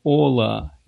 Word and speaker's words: hola: Порядный гист пишет, hola, H не читается.